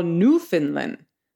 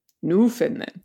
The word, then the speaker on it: Newfoundland: Since we've expressed interest in pronouncing places' names like the locals do, I believe that you've earned the right to pronounce Newfoundland as Canadians do. Oh. So forget that Newfoundland nonsense and call Newfoundland. Understand? Newfoundland.